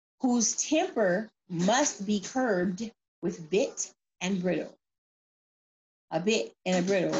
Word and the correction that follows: bridle: Did I say it right? Brittle, bridle, B-R-I-D-L-E.